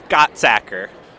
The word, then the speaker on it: Gottsacker: Gottsacker. – And it's easy. – It sounds exactly like it's spelled, but you wouldn't think it.